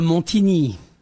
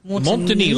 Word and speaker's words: Montigny: Monsieur Kurt de Montigny. – Montigny, like the beautiful Burgundy. – Right, Montigny. – Yeah. Montigny. – Montigny, yes.